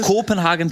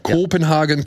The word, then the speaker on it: Kopenhagen: Ja, ne, Also Kopenhagen Cowboy mit langem O. – Genau, Kopenhagen Cowboy.